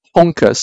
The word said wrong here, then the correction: Honkai: Star Rail, I hope I pronounce it, or Honkai. – Honkai, yeah.